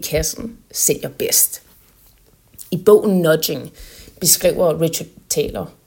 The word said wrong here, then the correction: Thaler: Richard Thaler,